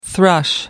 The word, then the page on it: thrush: American English